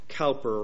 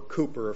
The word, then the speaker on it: Cowper: This was written by William Cowper, or Cooper, I've heard it pronounced both ways.